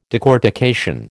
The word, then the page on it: decortication: American English